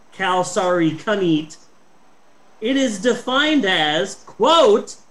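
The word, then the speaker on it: kalsarikännit: K-A-L-S-A-R-I-K-A-N-N-I-T. It's pronounced kalsari kanit, and it is defined in Finnish.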